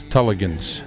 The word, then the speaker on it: Tulligan's: Yeah, it's Tulligan's Irish Pub. – Tulligan's. – Yeah. – Okay. I was going to say Tulligan's because it's spelled. – Either way is correct.